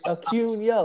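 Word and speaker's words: Acuña: And he turns around and goes, it's Acuna. Like, yelled at him, like, you can't even say his name.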